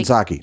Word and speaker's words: Psaki: I mean, we had uh, Jen Pataki, how do you say her last name? She was over at MSNBC. Um, Jen Psaki.